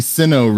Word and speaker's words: Sinnoh: And for people who are into this sort of thing, Jacob, it takes place in the Sinnoh region.